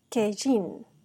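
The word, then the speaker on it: Kejin (קַיִן): También podemos notar que el nombre de Caín en hebreo se pronuncia Kejin, que significa lo tengo, posesión o adquisición.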